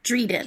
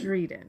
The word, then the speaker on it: Drieden: The word is spelled Drieden. Mm-hmm. And it rhymes with Sweden. – Okay. So it is the royal family of Dreden. – Dreden, yes.